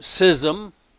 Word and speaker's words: schism: that there should be no schism. That's how you pronounce it. You don't pronounce the C-H in that word. Schism, not schism. It's schism in the body, but that the members should have the same care one for another.